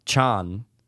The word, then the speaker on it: Chon: It is, I believe it's pronounced Chon. C-H-O-N.